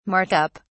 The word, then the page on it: Mark up: British English